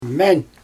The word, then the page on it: men: Xyzyl